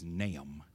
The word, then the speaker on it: Nahum: When we talk about Nahum, and that's the way it's pronounced, I've heard some people say Nahum, but that's not the appropriate pronunciation of the word. The proper title is Nahum, the emphasis on the A, Nahum, Nahum.